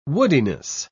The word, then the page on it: woodiness: British English